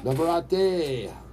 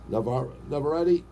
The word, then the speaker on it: Navarrete: Emmanuel Navarrete, that's what I call him, okay? That's how I pronounce his name. Because we don't pronounce our R's here in Boston. You know, Pataka, right? That's why I go, Navarrete.